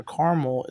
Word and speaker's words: caramel: However, Carmel is actually an accepted pronunciation of caramel.